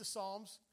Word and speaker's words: Psalms: Now, when I was a little young man, I thought that, you know, my view of the Psalms, once I understood there was a book in the Bible called the Psalms, and that you don't pronounce the P, which, what do you do with that?